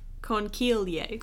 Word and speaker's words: Conchiglie: So my next one is the it's pronounced conchilia a conchilia. Conchiglie. Conchiglie, which is the seashell pasta.